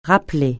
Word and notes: rappeler: francais

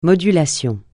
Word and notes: modulation: francais

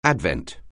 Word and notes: advent: anglais

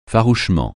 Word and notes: farouchement: francais

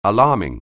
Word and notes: alarming: English

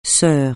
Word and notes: sœur: francais

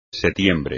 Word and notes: setiembre: espagnol

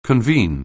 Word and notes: convene: anglais

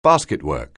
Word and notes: basketwork: anglais

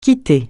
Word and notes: quitter: francais

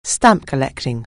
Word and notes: stamp collecting: anglais

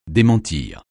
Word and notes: démentir: français

